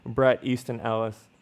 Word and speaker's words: Bret Easton Ellis: But which I think it is. – Bret Easton Ellis, which I think I mispronounced his name earlier, so I'm gonna actually say his name, Bret Easton Ellis.